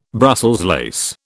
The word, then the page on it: Brussels lace: British English